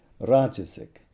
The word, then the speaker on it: rajasic: The gunas are all pronounced with short vowels, so it's sattva, tamas, rajas. But when they become adjectives, that first vowel is elongated, so then it's sattvic, rajasic, tamasic.